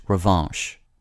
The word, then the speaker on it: revanche: The word of the day for February 27th is revanche, spelled R-E-V-A-N-C-H-E. The N isn't pronounced, but the vowel is nasalized, revanche.